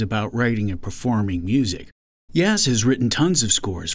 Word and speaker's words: Yaz: He's known to his colleagues and students as Yaz, rhymes with jazz.